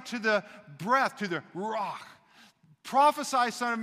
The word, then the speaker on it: ruach: and it's ruach, and it has this guttural sound at the end, ruach.